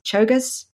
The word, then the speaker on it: Czolgosz: We have, I'm going to mangle his name, I never can say it right, Leon Czolgosz? – Czolgosz. – Well, there you go, I knew I was going to mangle it. – I know, Czolgosz.